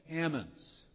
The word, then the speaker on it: almonds: And if you say almonds there, they say, wait a minute, those are not almonds, those are almonds. You don't say salmon, you say salmon. That L is silent. So it's almonds.